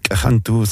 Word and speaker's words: cajantú: No sé si es aymara cantuta, porque también existe en Bolivia cantuta, pero cajantú se pronuncia aquí en Huancayo, la flor representativa de los incas.